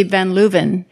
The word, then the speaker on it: Van Leeuwen: Okay, but if it's a Dutch name, I think it would be Van Leeuwen because you would pronounce that W with a V.